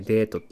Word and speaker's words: 戦争: これね、あの指導たちの、まあ、戦争って書いてデートって読ませるんですけど、まあね、その竹田さんのキャラがね、始めましょう。